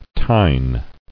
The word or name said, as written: Tine